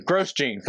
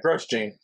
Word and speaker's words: Grosjean: Yeah, on lap 72, Scott McLaughlin and Roman Grosjean, I guess that's how you say it. I'm learning. Like Grosjean. – That sounds right. – Yeah, Grosjean.